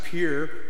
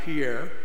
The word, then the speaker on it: Pierre: 30 years ago, in my first call in Pierre, South Dakota, and by the way, you Alabamans, it's pronounced Pierre, not Pierre.